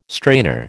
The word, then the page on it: strainer: American English